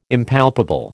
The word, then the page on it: Impalpable: American English